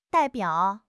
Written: dai4 biao3

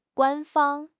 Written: 官方

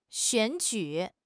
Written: 选举

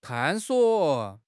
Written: 探索

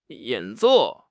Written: 演奏

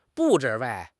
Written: 布置

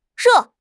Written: re4

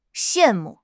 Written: xian4 mu4

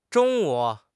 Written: zhong1 wu3

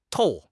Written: qiao1

